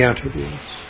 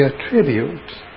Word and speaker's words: attribute: What are the attributes? They are the qualities that God has. But we attribute, pronunciation changes, right? Attribute for noun, attribute for the verb.